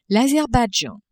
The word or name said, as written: Azerbaidjan